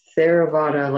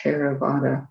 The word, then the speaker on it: Theravada: Okay, and then TH is an aspirated T. It's not like th, like we don't say Theravada, like the word therapy, although it's a nice link, I guess, but that would be a Theravada. So a T with an aspiration after it, Theravada.